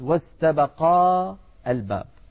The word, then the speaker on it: واستبقا الباب: هذه الألف تسقط في الوصل للتخلص من التقاء الساكنين. في سورة يوسف عن يوسف وامرأة العزيز واستبقا الباب، واستبقا الباب، لما نصل ألف استبقا تسقط للتخلص من التقاء الساكنين، فيصير النطق واستبقا الباب واستبقا الباب.